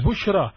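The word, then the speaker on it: Bushra (بشرى): Bushra, ba, sheen, ra, and ya, maqsura, which is pronounced as an alif.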